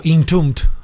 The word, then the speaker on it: Entombed: En este caso, pues el dedicado a la banda sueca Entombed, o Entombed, si eres británico.